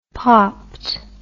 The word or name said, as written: popped